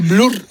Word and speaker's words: Blur: ¿Esto se dice Blur o cómo se dice? – Blur. Sí, Blur.